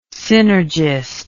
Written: synergist

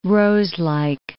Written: roselike

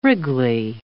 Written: wriggly

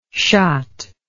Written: chott

